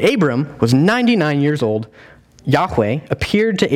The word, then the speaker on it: Yahweh: So whenever you see capital L-O-R-D in your Old Testament, that's actually code word for God's personal name, Yahweh, is the Hebrew way to say it, or the English way of pronouncing it is Jehovah.